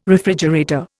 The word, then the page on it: refrigerator: Indian